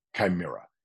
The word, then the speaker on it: chimera: They think they do, but they chase a chimera, or the right pronunciation is chimera. It's funny because chimera, I thought it was this word that existed. My son, you know, corrected me a year ago, Dad, it's chimera, which means an illusion, an unobtainable goal, something that's a mirage.